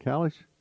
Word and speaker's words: caliche: And it does not include building, stone, limestone, calix, how you pronounce that? Caliche.